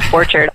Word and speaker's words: Borchard: It is Borchard.